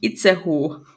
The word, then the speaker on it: Itzehoe: Aber wenn ich jetzt Otzehoe eingebe, finde ich Itzehoe. – Itzehoe! Okay. – Aber in meiner Recherche stand Otzehoe. Vielleicht. – Itzehoe!